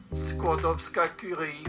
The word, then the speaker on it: Skłodowska Curie: Skłodowska Curie.